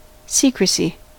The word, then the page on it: secrecy: American English